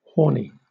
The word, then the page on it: horny: British English